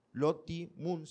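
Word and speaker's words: Lottie Moon: Loti Moon, se escribe L-O-T-T-I-E, Loti Moon.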